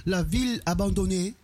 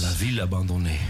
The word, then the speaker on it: La Ville Abandonnée: Ik hoop echt dat ik het goed heb uitgesproken. – Dat spreek jij mooi Frans. – Ja, het zei ze heel Frans. – La Ville Abandonnée.